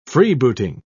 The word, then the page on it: freebooting: British English